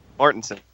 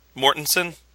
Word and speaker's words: Mortensen: Real quick, how is Viggo's last name pronounced? – Mortensen. – Mortensen? Okay. Just wanted to confirm that for this intro.